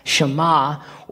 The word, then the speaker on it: Shema: This word in the Hebrew I've talked about a lot is Shema, or another pronunciation is Shemaya, which means to hear or perceive.